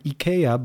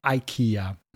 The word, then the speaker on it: IKEA: And IKEA, by the way, is the correct pronunciation. All right? Not IKEA.